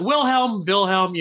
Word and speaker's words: Wilhelm: Yeah. Wilhelm, you know, it's a German name.